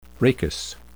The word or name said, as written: rachis